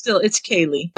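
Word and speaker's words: ceilidh: Okay, how do you pronounce the name of the stupid dance? – Kelly. Kelly. It was in the text of the story. – Pronounced Kelly, yeah. It's. – Yeah, but it's Kaylee.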